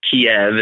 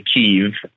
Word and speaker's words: Kyiv: And by the way to pronounce Kiev is actually Kyiv.